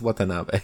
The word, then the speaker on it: Watanabe: Ken Wantabe. – Wantabe what? – I don't know. Watanabe. – I think it's Watanabe. – I don't know how to pronounce it. All right. Wasabi. As Dr. Ishiro. – Yeah, it's Watanabe.